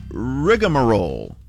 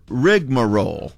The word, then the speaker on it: rigmarole: The word of the afternoon is rigmarole or rigmarole. You can say it in different ways. – Spell that for me. – Well, the first way is R-I-G-M-A-R-O-L-E.